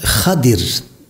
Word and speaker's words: Hızır: Arapçada Hadir diye telaffuz edilir. Türkçe'ye biz Hızır diye onu aktarmışız.